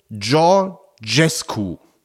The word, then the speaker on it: Georgescu: Der ausgeschlossene Präsidentschaftskandidat wird ausgesprochen Georgescu. Das J jeweils weich, wie Georg im Englischen.